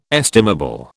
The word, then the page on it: estimable: British English